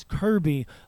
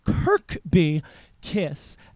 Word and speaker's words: Kirk B. Kiss: I thought it was Kirby, like the video game character, but it is Kirk B. Kiss.